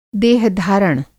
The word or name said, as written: देहधारण